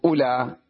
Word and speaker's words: أولى: وأولئي وأولى، لاحظوا أولى الواو لا تكتب ولا تلفظ، وأولئي أيضا الواو بعد الهمزة تكتب ولا تلفظ، أولى وأولئي، وإن شئت تدخل عليها الهاء فتقول هؤلاء، تمام.